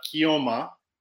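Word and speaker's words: Keoma: and I always mispronounce it. You're going to probably have to correct me over and over again, but Kioma starring Franco Nero. – That's it. Yeah.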